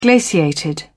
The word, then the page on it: glaciated: British English